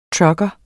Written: trucker

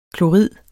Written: klorid

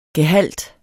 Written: gehalt

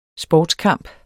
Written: sportskamp

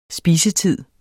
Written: spisetid

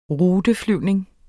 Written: ruteflyvning

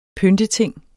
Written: PYNTETINGEN

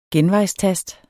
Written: genvejstast

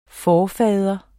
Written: forfader

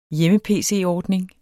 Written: hjemme-pc-ordning

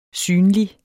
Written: synlig

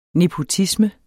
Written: nepotisme

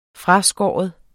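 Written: FRASKÅRET

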